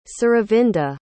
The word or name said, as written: suravinda